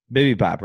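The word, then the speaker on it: Bibibop: Is that how you say it? – Bibby Bop? – Bibby Bop, right? – Yeah.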